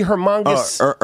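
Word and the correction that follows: Hernangómez: Hernan Gomez, yeah. – Is that how they say it? Is that how we're saying it for today? – Hernan Gomez, if you want to impress the Dominicans.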